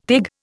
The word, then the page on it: teg: Indian